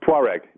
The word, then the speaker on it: Tuareg: The one where he finds this would you pronounce it a Tuareg? – Tuareg, yeah.